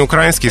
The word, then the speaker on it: украинский: Тогда, в XIX веке, правильно было такое ударение. Но все в языке меняется, норма меняется, и сегодня, сейчас, э, в наши дни — а, ударение украинский в словарях фиксируется как устаревшее и как нерекомендуемое. Сегодня правильно говорить украинский. Украина, украинцы, украинский.